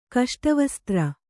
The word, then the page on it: ಕಷ್ಟವಸ್ತ್ರ: Kannada